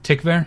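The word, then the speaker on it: Tykwer: And of course, people know Werner Herzog and Wim Wenders and Tom Teichwer. Is that it? Am I saying that Tikva. – One right? Tikva? – Mm-hmm.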